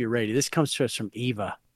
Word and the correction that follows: Eva: My name is Ava, and she said it's pronounced Ava, even though it's spelled Eva.